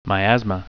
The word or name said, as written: miasma